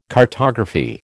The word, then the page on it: cartography: American English